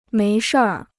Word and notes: 没事儿: méi shìr

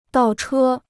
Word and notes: 倒车: dào chē

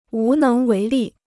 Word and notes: 无能为力: wú néng wéi lì

